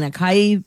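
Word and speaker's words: açaí: A-C-A-I. I've been saying acai powder, stuff like that. It's really ah and then sigh and then e, acai. That's how you say it.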